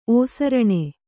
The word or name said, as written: ಓಸರಣೆ